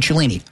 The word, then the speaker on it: Cellini: Download, again, the app, Fliff, F-L-I-F-F, and use the promo code Cellini. Obviously, if you don't know, that's my last name, and it's spelled C-E-L-L-I-N-I.